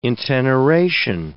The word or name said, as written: inteneration